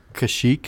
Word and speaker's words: Kashyyyk: That Kashyyyk? How do you say Chewbacca's home planet? – Kashyyyk. – Kashyyyk.